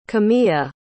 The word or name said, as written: Kamya